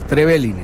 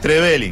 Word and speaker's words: Trevelin: Lo que me dejó este argentino A que aprendí a pronunciar, Trevelin. – Ah, Trevelin. Está bien. – No es Trevelin. Está bien. – Sí. Así que me corrigieron todos los muchachos de Trevelin que entraban. Pronuncia bien.